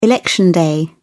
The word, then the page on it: election day: British English